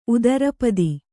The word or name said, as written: ಉತ್ತರಪದ